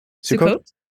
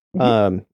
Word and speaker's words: Sukkot: or I can't remember how to pronounce it. It's Sukkot? – Yeah, Sukkot. – Sukkot.